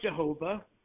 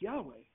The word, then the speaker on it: Yahweh: So it's not Jehovah. It's the closest, it's YHWH. We don't know how it was pronounced. But Jehovah? It's not. It's pronounced Yahweh. That's the correct pronunciation.